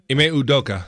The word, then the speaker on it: Ime Udoka: Ime Udoka, is that how you pronounce his name? – Ime Udoka.